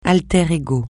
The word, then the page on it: alter ego: francais